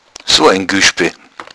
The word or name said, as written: gueschbe